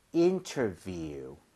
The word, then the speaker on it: interview: 거기 view이기 때문에 V는 입술이 붙으면 안 됩니다. – 네. – So 아랫입술 위에 윗니 살짝만 올리고 바람을 뿜어주세요. 자, 갑니다. View, interview.